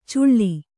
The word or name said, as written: ಚುಳ್ಳಿ